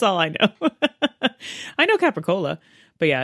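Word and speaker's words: capicola: Which I'm pretty sure is, I think it's supposed to be Capricola. – Yes. – But it ends up being pronounced, and it sounds like gabagool.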